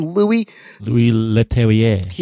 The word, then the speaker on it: Louis Leterrier: Louis Leterrier.